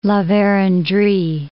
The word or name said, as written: La Verendrye